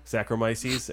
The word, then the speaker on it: Saccharomyces: Saccharomyces.